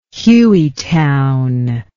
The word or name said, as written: Hueytown